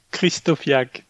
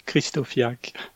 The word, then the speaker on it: Krysztofiak: Krysztofiak. Patrice Krysztofiak.